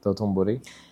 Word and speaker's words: Dotonbori: Dotonbori.